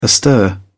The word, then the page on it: astir: British English